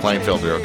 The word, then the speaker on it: Klienfiltr: It looks like Klienfiltr. Did I say that right? Klienfiltr, okay.